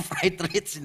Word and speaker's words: Friedrich: Nah kalau seorang yang bernama Friedrich Nietzsche, ini bacanya Friedrich Nietzsche, orang Jerman. Jadi ini jangan dibaca pakai bahasa Inggris ya. Kalau dibaca pakai bahasa Inggris, nama depannya agak lucu. Fried rich.